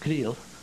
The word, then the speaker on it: Creel: They pronounce it Creel. But it's C-R-E-E-L, Chihuahua, about 8,000 feet elevation, down in the pine forest.